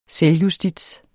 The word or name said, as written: selvjustits